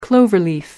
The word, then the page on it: cloverleaf: American English